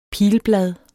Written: pilblad

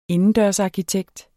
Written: indendørsarkitekt

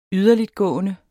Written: yderligtgående

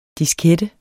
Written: diskette

